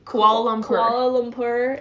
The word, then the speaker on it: Kuala Lumpur: Kuala Lumpur.